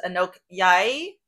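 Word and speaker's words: Anouk Aimée: So alongside was Anouk Yai. That's how you say her name, right? – Yeah.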